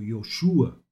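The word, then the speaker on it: Yoshua (ישוע): O nome Jesus é de origem hebraica e é pronunciado como Yoshua.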